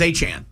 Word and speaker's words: Etienne: Travis Etienne. Yep. Somehow his name is Achan.